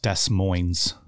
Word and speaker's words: Des Moines: Yeah believe it's pronounced Des Moines by the locals.